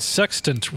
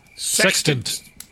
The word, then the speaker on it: sextant: T-E-T? – Like sex tant, only when the tent is a tant. – Oh, tant. – So it's like sex tant.